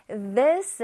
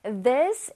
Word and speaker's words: this: This is. TH가 있으니까, 얘들아. 혓바닥으로 실뜨기 해야지. 쌤이 맨날 말하는 거. This is.